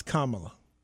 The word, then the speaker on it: Kamala: So I do want to make a slight correction because I was joking at first, but just so we know, it's Kamala. That's how you pronounce it.